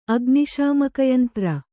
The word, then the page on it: ಅಗ್ನಿಶಾಮಕಯಂತ್ರ: agniśamakayantra